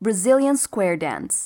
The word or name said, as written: brazilian square